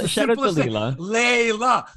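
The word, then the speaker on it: Leylah: the commercial, for everyone to hear, I'm Leila Fernandez.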